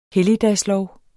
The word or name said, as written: helligdagslov